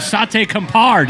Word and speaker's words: Sate Kampar: We've got Ange Branca, owner and chef of Sate Kampar.